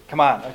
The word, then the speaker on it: Kaman: Is it Kaman or Kaman? – Kaman. – Okay. I always hear two different versions.